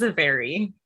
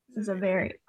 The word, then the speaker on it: Xiveri: And she is the Xiveri, how do you say it? – I say it's Xiveri. – Xiveri, what? – What? – No. – I should be writing this down right now. I'm just going to say it wrong. Oh my God. Say it again. – Xiveri.